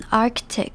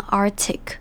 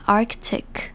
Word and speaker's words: Arctic: found 这边的 O-U 是发成 L 的声音， found that if the Arctic sea ice melts。接着我们来看 Arctic 这个字。这个字要注意的地方，第一个是它的重音在第一个音节，就是 A-R-C 那边念成 Arctic。那这边要注意的是 ARC，它的 C 是要发音发成一个可的声音， Arctic，注意 ARC 的 C 是要发音，不能直接念 Arctic，而是要念成 Arctic。